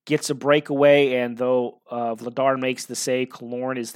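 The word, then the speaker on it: Vladař: Vladar, I believe is how his name is pronounced.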